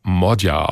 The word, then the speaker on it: Magyar: Er schreibt sich zwar Peter Magyar, also mit Y, spricht sich aber Modyar aus.